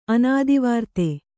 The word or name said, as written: ಅನಾದಿವಾರ್ತೆ